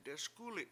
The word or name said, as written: deSkUli7